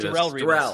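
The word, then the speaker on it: Darrelle Revis: Darrell Revis.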